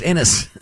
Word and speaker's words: Enes: I would have thought a guy named Anus would, if you're going to change your name, that'd be the part you'd change. – It's actually pronounced Enes. Enes. Yes. That's not what you said.